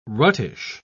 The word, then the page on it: ruttish: British English